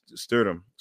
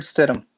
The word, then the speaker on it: Stidham: First, they get themselves a backup quarterback and Jared Stidham. Oh, God, I said his name wrong. – Jared Stidham. – Stidham. Yeah.